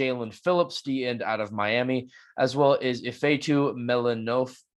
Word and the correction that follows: Melifonwu: Wait, there's a thing blocking it. Melifonwu. Yes, I am bad at pronunciations.